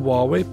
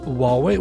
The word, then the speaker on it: Huawei: Og på Tokyo-turen så hadde jeg fått lånt med meg en Huawei P30-telefon. Eh, ja, som by the way skal uttales Huawei og ikke Huawei, slik som reklamen deres gjør.